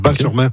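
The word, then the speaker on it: Batz-sur-Mer: Et surtout, la crêperie s'appelle le Dervin à Bas-sur-Mer, qui font les meilleures crêpes de la Oui, Bat, moi, on prononce Bas, le T ne se prononce pas, Bas-sur-Mer.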